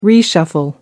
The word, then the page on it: reshuffle: American English